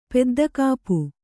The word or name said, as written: ಪೆದ್ದಕಾಪು